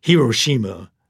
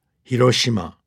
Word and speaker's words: Hiroshima: For example, we often hear Hiroshima for that first city in the history of the world that got bombed with an atomic bomb. It's pronounced very flat, Hiroshima, not this rhythmic Hiroshima.